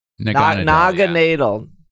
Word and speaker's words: Naganadel: catches how do we say this? Nagandadel? – Naganadel. – That's it. Naganadel.